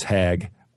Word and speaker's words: Haag: I think I think her name is Hag, but maybe I'm saying that wrong, and maybe it's partly intentional. But uh, anyway, it's H A A G.